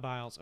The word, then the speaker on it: Biles: I just read a little bit on the how do you say her name? The like girl who's like the best in the world at Olympic on our USA. – Simone Biles. – Okay, it's Biles. I wasn't sure how you say it. – I think it's Biles.